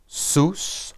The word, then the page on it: sus: Hebrew